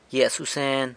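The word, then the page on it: gyia susen: San Lucas Quiaviní Zapotec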